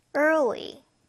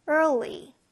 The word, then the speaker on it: early: early. er 할 때는 r 사운드 때문에 입안에 어디에도 혀가 닿지 않고요. we 할 때는 혀를 앞으로 뺀다는 느낌으로 읽어주세요. early. early.